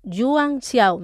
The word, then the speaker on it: Yuan Xiao: Xuanxiao. Perdón se lo he dicho mal, Yuan Xiao, mejor dicho,